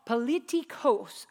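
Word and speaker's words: politikos (πολιτικός): So politics comes from a Greek word, and it's politikos, or kos. Politikos. I actually listened to the pronunciation of the word because I wanted to get it right.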